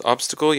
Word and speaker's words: obstacle: So obstacle, you have the emphasis on the first syllable, obstacle. And the translation for the word obstacle in Chinese would most likely be, in most cases, it would be zhang ai.